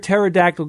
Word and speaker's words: pterodactyl: Why can't you hear a pterodactyl go to the bathroom? – I don't know. Why can't I hear a pterodactyl go to the bathroom? – Because the pee is silent.